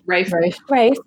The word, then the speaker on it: Ralph: Oh my God, Ralph, or Rafe, how do you say it? – Rafe.